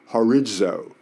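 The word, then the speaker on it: horizo (ὁρίζω): So that word determined in the Greek is a word that we would pronounce horizo, which we get our word horizon from, which basically means the determination, the end of something, the limit that it can go to.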